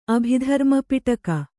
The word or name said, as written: ಅಭಿಧರ್ಮಪಿಟಕ